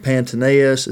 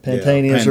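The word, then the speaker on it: Pantaenus: Pantaneus. Is that how you say it? Pantaneus or what? – Yeah.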